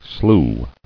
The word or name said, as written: Sloughing